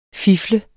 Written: fifle

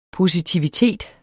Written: positivitet

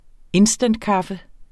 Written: instantkaffe

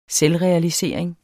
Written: selvrealisering

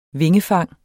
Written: vingefang

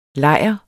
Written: lejr

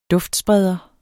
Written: duftspreder

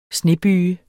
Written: snebyge

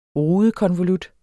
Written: RUDEKONVOLUT

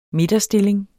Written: midterstilling

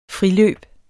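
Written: friløb

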